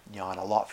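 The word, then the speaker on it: ñāṇa: Spanish N-A-N-A, there's a long A in there, jnana, first A is long, I think, jnana.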